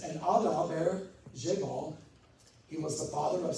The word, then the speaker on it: Jabal: That's how it's pronounced in the Hebrew. Jabal, however you want to say it.